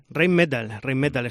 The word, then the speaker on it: Rheinmetall: Pues entiendo que ha influido, ¿no?, en la economía local, la expansión de las fábricas de Rheinmetall. Hombre, creo que lo he dicho bien, ¿no? Rheinmetall, ¿no? – Rheinmetall, está perfectamente dicho en alemán.